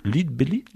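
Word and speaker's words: Lead Belly: comment on prononce Leadbelly? – Ah oui, mais tu prononces bien. – Leadbelly.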